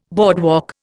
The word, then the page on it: boardwalk: Indian